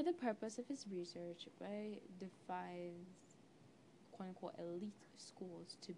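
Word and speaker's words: Wai: Y is particularly, it's either Y or Wei, it's W-A-I, that's how it's pronounced.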